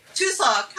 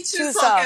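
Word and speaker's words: Chuseok (추석): Chuseok. Happy Chuseok, everybody.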